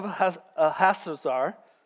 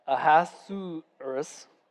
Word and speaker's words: Ahasuerus: In the first year of Darius, the son of Ahasuerus, I just butchered that, Ahasuerus,